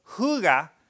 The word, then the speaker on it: hygge: Is anybody familiar with the Danish word hygge? And it is pronounced hygge, which is this concept, this Danish concept of embracing a coziness.